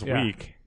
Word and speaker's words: WEC: It's pronounced weak. – W-E-K.